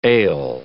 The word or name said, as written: ale